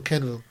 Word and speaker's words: Cavill: How do you say his last name? Cavill or? – No, Cavill. Cavill. – I don't care. Cavill? He looks good without a shirt. – There's no N in it.